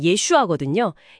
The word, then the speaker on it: 예슈아: 신약의 주인공인 예수라는 이름 자체가 히브리어로 발음하면 예슈아거든요.